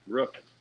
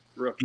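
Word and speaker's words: Ruch: Rook. Rook.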